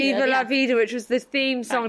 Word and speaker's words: Viva la vida: Diva la vida, the diva, de, I always say it wrong. – Viva la vida.